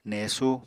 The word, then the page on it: nesú: Apatani